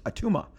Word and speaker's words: Attuma: His name's Attuma. It sounds like Arnold Schwarzenegger saying Atuma in Kindergarten Cop, but it's literally pronounced Atuma,